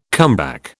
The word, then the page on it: comeback: British English